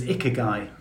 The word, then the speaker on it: Ikigai: It's Ikigai.